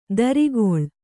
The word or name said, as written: ದರಿಗೊಳ್